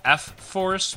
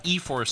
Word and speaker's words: eForce: But from what I understand, eForce, which is probably supposed to be pronounced like F-Force, but eForce sounds much more badass.